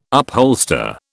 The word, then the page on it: upholster: British English